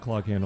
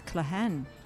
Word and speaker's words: Cloghane: In a place called Clog Hane on the in Ireland. – I think it's Clahan. You don't say the clog. – You don't say the clog, but it's spelled Clahan. C-L-O-G-H-A-N-E.